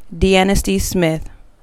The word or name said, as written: Deannasty Smith